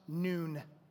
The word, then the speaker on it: Nun: His father is Nun, pronounced Noon.